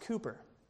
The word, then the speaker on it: Cowper: There was a man who lived in the 1700s named William Cowper, spelled C-O-W-P-E-R, Cowper, but pronounced Cooper.